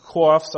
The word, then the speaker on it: coif: And he said, others are to a certain extent covered over the region of the brain with linen coifs. I don't know how to pronounce that word. C-O-I-F. Help me out, teacher. Coif. Coif. French. Sounds French.